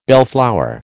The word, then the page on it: bellflower: American English